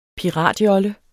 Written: PIRATJOLLE

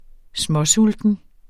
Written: småsulten

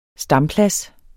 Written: stamplads

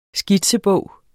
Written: skitsebog

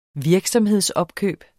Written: virksomhedsopkøb

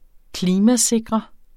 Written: klimasikre

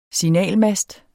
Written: signalmast

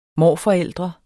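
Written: morforældre

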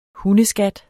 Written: hundeskat

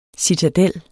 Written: CITADELLET

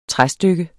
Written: træstykke

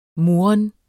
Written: murren

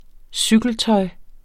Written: cykeltøj

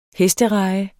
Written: hestereje

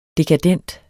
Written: dekadent

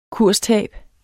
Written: kurstab

